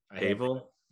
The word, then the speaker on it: Cavill: Cavill? – Yes. – Cavill? – Bill? – Cavill? Oh, Henry Cavill. – Henry Cavill.